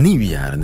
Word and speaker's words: nieuwjaren: Nieuwjaren. De klemtoon ligt op het nieuw.